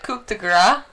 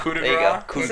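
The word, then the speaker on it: coup de grâce: Coup de grace? – Yeah. – The coup de grace. – Say it again. – The coup de grace. – Coup de grace. – Oh, even worse. Even worse.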